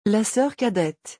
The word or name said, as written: La soeur cadette